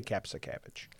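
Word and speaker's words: Kapszukiewicz: but I say Capsicavage.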